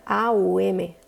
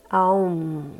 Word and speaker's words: Om: OM se escribe. Pero cuando se pronuncia, se pronuncia AUM, AUM, y la M se extiende.